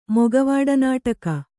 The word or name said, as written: ಮೊಗವಾಡನಾಟಕ